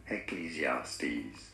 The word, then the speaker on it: Ecclesiastes: Ecclesiastes.